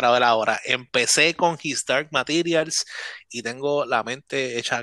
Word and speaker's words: His Dark Materials: His Dark Materials.